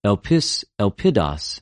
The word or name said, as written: ἐλπις,ἐλπιδος